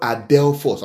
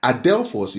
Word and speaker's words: adelphos: You see, the word there, brethren, is from the Greek word adelphos. I'll spell that for you. Adelphos is A-D-E-L-P-H-O-S. Adelphos.